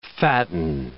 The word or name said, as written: fatten